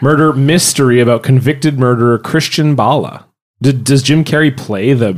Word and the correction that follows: Bale: Did you just pronounce Christian Bale Christian Bala? – That's the Serbian Christian Bale. yeah, I just put an accent on the E that didn't exist before.